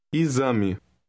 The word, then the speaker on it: exame: In word 5, the X is pronounced like a Z. Listen and repeat. – Exame.